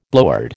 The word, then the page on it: blowhard: American English